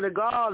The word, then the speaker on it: De Gaulle: Tell me if I'm saying this right. General De Gaulle Drive in Mar. – Yes, General De Gaulle, that's right over there, not far from where General Rico lives.